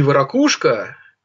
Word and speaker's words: варакушка: Кстати, не варакушка, не варакушка, а именно варакушка. Вот запомните, как ставится ударение.